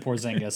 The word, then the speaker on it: Porzingis: You said it right when you said Porzingis.